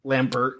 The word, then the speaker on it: Lambert: So she saw Chris Lambert and was like, "Is it Lambert? Is that how you pronounce it? Because it's French." – Sure, Lambert.